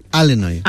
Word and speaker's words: Illinois: ¿Sabes que hay, como aquí en España, hay acentos diferentes en Estados Unidos y los nativos del estado de Illinois lo pronuncian Illinois?